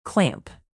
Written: clamp

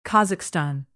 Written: kazakhstan

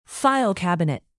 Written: file cabinet

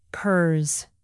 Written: purrs

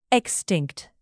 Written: extinct

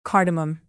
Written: cardamom

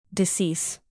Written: decease

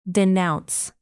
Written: denounce

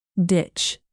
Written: ditch

